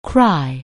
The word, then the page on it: cry: anglais